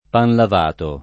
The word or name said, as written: pan lavato